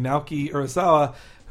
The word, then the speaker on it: Naoki Urasawa: And it's by Naoki Urasawa. Mm-hmm. I'm going to say it correctly. Naoki Urasawa, who, in case you didn't know, he created 20th Century Boys, like favorite manga I've read in a very long time.